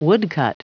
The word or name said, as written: woodcut